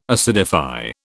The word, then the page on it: acidify: British English